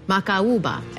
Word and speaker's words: Macaúba: I hope I said that correctly. – Macaúba. – Macaúba. – M-A-C-A-U-B-A. It's hard when those vowels are dancing with one another, the A and the U.